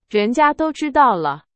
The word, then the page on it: 人家: Chinese